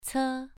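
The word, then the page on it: ce: tone 2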